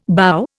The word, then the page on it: bough: Indian